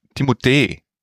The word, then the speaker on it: Timothée: Timothy. – Timothy. – Timothy Chalamet. – Timothée eigentlich. Timothée. Ne? Also, er korrigiert die Leute aber nicht. Ich glaube so, ne? Spricht man das aus auf Französisch.